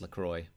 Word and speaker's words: LaCroix: Yeah, I believe that's pronounced LaCroix. Like the sodas.